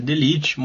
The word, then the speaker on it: De Ligt: Sobre a pronúncia do nome, eu sei que o correto é De Ligt, mas assim como Henry Chan, eu me recuso, então será De Ligt, enquanto esse jogador ainda não estiver no livro.